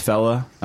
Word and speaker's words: Phaeleh: I pronounce it Fela, but it's Fela. It's Phaeleh, and it is must listen material.